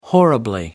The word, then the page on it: horribly: American English